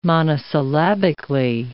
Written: monosyllabically

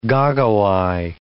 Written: goggle-eye

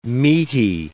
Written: meaty

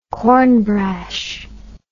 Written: cornbrash

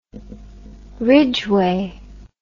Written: ridgeway

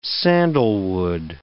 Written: sandalwood

